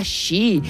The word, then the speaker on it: Xi: Xi. – Es Xi, Xi.